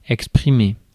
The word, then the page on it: voice: /vɔɪs/; American English